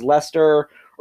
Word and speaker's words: Leicester: I mean, there's also like what looks like Leicester, or Leicester is Leicester, or Worcester is Worcester, that is how you say Worcestershire sauce.